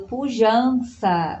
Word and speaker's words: Pujança: E queria indicar uma produtora audiovisual só de mulheres negras brasileiras chamado Pujança, mas se escreve Pujanca.